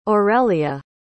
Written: orelia

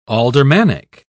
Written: aldermanic

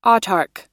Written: autarch

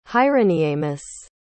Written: hyroniemus

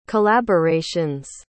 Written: collaborations

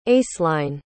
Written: aceline

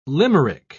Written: limerick

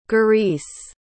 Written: gurice

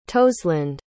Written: toseland

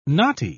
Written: knotty

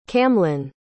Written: camlann

